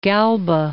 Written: Galba

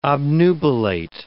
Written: obnubilate

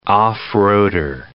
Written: off-roader

